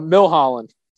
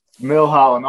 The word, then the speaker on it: Milholen: How do you say it? I got to know before you go forward. – Milholen. – Milholen. All right. That was close.